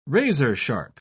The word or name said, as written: razor-sharp